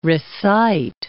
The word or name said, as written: recite